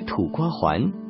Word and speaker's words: 土瓜湾: 香港粤语经常把阴平的湾读作阳平的环，所以听起来像是土瓜环。土瓜湾的名称由来有不同说法，一个说法是土瓜湾旧日的海湾海心岛的形状像土瓜而得名，另外一种说法是当时那里的客家人以种植土瓜为主而得名。